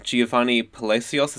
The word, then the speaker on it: Geovany Palacios: And today we are joined by special guest, Giovanni Palacios.